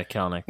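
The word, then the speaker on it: Kelenic: The hitting has been and at the beginning of the year when Jared Kelnick, I can never say his name. – Yeah, Kelnick.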